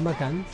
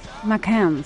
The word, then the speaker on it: Macans: Se escribe Macanes, pero es Macans. – Macans.